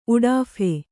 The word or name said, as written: ಉಡಾಫೆ